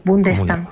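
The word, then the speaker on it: Bondestam: Y Linda von der Stam, esa O se pronuncia como una U, Bundestam.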